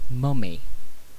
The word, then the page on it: mummy: British English